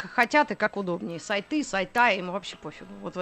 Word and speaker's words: сайты: Да, программисты говорят не сайты, а сайта. В профессиональной речи характерно как раз вот это ударение, да, это, окончание не «ы», а «а».